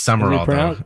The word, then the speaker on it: Sumrall: He became part of S-U-M-R-A-L-L. – I know, it's weird. It is Summerall. – Is it pronounced Summerall? – Yeah. – I feel like there's a letter missing. – It is. It's not spelled right, but that's how it's pronounced.